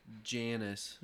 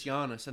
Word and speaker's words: Janus: Janus. – Yep. Janus, the god of doors and gates. – No, it's Giannis, and that's where we get our word for janitor, because they're the keeper of the keys.